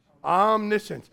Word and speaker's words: omniscient: Omniscient. It's spelled O-M-N-I, omni, science. Omniscience. God is all-knowing.